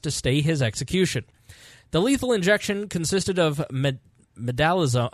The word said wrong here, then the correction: midazolam: I should have looked how to how to uh, actually pronounce this. Uh, mid midazolam. Yeah, midazolam. That's how what we'll go with.